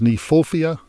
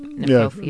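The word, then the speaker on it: Kniphofia: The one I think we can agree on is Nephophia. You tend to want to pronounce the K. – Right, but it's K-N, right? – Nephophia.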